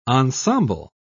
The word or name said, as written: ensemble